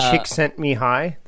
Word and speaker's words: Csikszentmihalyi: Just say Chick sent me high. That's it.